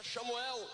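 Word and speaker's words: Shamuel (שמואל): Na pronúncia hebraica, Samuel, nós podemos entender, como os judeus mesmo entendem o nome Samuel, que é Shamuel, que quer dizer Shem, é nome, nome dado por Deus.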